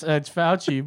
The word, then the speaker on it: Fauci: I keep forgetting it's Fauci, but I keep saying Faucho Marx.